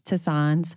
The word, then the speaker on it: tisanes: And so these are the ones that herbal tea, herbal infusions, um, as Mimsy let me know, it's pronounced tisanes.